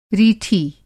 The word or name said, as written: रीठी